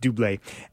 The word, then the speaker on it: Dublé: Spelled double, it's pronounced Dublé, and the original not available on Rhapsody.